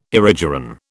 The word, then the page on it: erigeron: British English